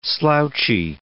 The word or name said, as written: slouchy